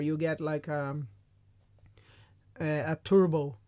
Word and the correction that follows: turbot: Turbot. – Turbot.